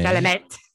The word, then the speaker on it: Chalamet: Chalamet. – Chalamet. It's not Chalamet. – Whatever. Chalamet, Chalamet.